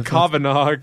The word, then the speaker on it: Kavanaugh: I'm gonna butcher this last name. Kavangov. – Oh Jesus! You're butchering that so hard. It's Kavanaugh.